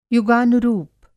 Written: युगानुरूप

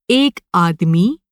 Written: आदमी

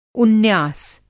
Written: उन्यास

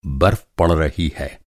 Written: बर्फ़

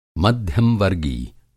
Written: मध्यम-वर्गी

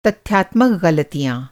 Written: तथ्यात्मक

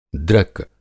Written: दृक

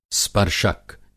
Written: स्पर्शक

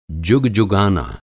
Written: जुगजुगाना

